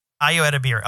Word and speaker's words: Ayo Edebiri: How do you pronounce her name? – Ayo Edebiri? – Yeah. Ayo Edebiri.